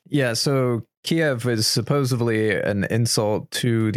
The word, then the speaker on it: Kyiv: They'll sober up in Kyiv, not Kiev, Kyiv, apparently.